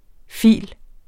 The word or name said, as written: phil.